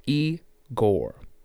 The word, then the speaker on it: Igor: This is Igor. Pronounced E-Gore.